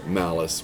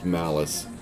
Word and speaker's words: Malice: But I will say that you finally see, like, they finally wrap up the whole Mollus, but you find out it's pronounced Malice, which